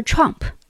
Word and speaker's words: Trump: 明明这个英文单词的发音就叫 Trump，川普是多么好的一个翻译，就是两个音节的单词嘛。